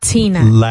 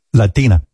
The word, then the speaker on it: Latina: Yeah, Latina. – Remember what I always say in Spanish. – Just remove the air from the T. It's not Tina. – Latina. – Latina.